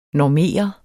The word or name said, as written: normere